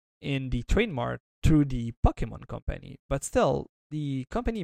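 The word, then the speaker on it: Pokémon: In fact, Tim Cook had to talk about it on the earnings call, and he mispronounced Pokemon. – Oh, Poke. – He said Pokemans, I think. – Pokemon, yeah, something like that.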